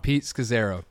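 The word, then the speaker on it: Scazzero: And forgive me for butchering his name, but Scazzaro? – That's actually exactly how you say Scazzaro. His name. Pete Scazzaro.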